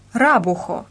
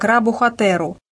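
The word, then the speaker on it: рабухатэру (ラブホテル): По-японски это произносится как «рабухатэру», а молодежью сокращается в сленговое «рабухо».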